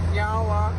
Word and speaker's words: nyawa: So in our language, we say nyawa, which means thank you. – Nyawa. – Nyawa. – I said it right? – Yeah, you did.